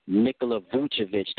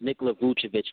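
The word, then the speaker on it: Nikola Vučević: Again, I mispronounced the guy's name. His name is Nikola Vucevic. That's Nikola Vucevic for the Orlando Magic.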